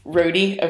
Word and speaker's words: Rohde: Rohde, okay. By Mr. Mike Rohde.